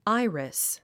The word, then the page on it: iris: ái ə ris; アイリス